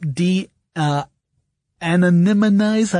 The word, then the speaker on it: de-anonymize: This can actually help de-anonymize.